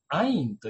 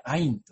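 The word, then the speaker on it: ア イン (עין): さらに目というね、言葉が一つのキーワードとして出てきますけれども、目というのはヘブル語ではアインというふうに言うんですね。まあ、志村けんのね、アインみたいですけれども、まあ、発音的には一緒ですね。アインと言います。で、ヘブル語でこの目という単語はですね、 3 つの文字からできているんですけれども、まず最初のこのアインという文字ですね。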